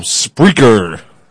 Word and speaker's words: Spreaker: We are on Spreaker.